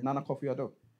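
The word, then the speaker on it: Nana Akufo-Addo: Nana Kofi Addo. – Okay. – I actually knew the answer. – You even pronounced that well. I love that.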